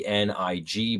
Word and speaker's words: Hoenig: So we we kind of start off the book here with the gentleman named Thomas um, Hoenig, and his his his name is spelled H O E N I G, but it's pronounced nig. So Thomas nig,